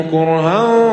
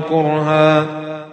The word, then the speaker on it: كره: حملته أمه كرها ووضعته كرها. – في القرآن كره بالضم وأحيانا بالفتح كره، والنطق بالضم أقوى وأشد، لذا جاء مع الثقل النفسي والبدني مثل: كتب عليكم القتال وهو كره لكم.